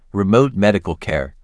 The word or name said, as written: Remote medical care